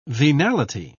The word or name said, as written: venality